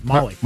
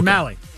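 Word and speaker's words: Mahle: Mahe? – Nope. – How am I saying it? – Molly. – Okay. – Or Mally. – Is it Mally? – Mally. – Okay. Okay. O'Malley. Like O'Malley.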